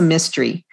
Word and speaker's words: Mistry: And it's a mystery. And in fact, the name of the main character, her last name is Mystery. It's spelled M-I-S-T-R-Y.